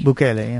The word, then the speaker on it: Nayib Bukele: Make sure I say this right. – Yeah. – Nayib Bukele. – Bukele, yeah.